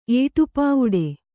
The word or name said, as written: ಏಟುಪಾವಡೆ